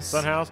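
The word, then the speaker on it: Son House: I need to check out this Sunrise guy. Is that right? Did I get it right? – Sunhouse.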